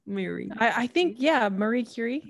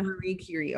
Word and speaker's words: Marie Curie: How do you say in English? Because we usually just say like that. Marie Curie. – Marie Curie. Yeah. – Marie Curie. Okay.